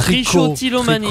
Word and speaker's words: trichotillomanie: Ça doit être trichotillomanie. – Tricot. Ça s'écrit T-R-I-C-H-O, mais c'est tricot.